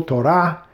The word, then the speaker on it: Torah: The accent on the second syllable, Torah.